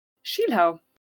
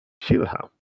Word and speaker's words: Schielhau: So first of all, this is the Schiler, or the Schielhau, how would you pronounce it? – Schielhau. – Schielhau. Brilliant.